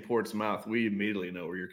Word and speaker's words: Portsmouth: Portsmouth, but spelt mouth. – It is spelled mouth, yeah. – All right, I'm not stupid. No, I ain't stupid. – You're good. – That's how dumb that city is. It's said Portsmouth, but it's spelled Portsmouth, but said Portsmouth. – That's how we know if you're an insider or an outsider.